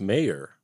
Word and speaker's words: Maier: I think it's pronounced Maier. Maier!